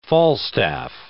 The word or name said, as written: Falstaff